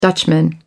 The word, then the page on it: dutchman: American English